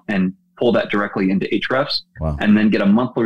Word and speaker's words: Ahrefs: Another one that actually will tell you exact specific things to look at, including some of the visible things, is a site called Ahrefs, which is Ahrefs. – Okay. – So it looks like Ahrefs, but it's Ahrefs is how they say it.